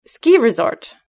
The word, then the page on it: ski resort: British English